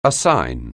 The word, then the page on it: assign: anglais